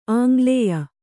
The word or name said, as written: ಆಂಗ್ಲೇಯ